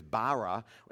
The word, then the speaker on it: bara: It's about the approximate pronunciation is bara, and it means created from nothing.